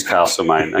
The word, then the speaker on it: Castlemaine: Castlemaine or Castlemaine? What's the correct pronunciation? – I've always said Castlemaine. I've been to the theatre Castlemaine, and they insist it's Castlemaine. – It is Castlemaine, okay. Now, what sort of ship is the Castlemaine? – Look, it's they call it the minesweeper, but...